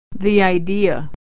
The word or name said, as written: idea